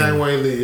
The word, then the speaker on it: Zhang Weili: Thug Rose versus Wei Li. Did I pronounce that correctly? I didn't see the full name. – Zhang Weili, yes.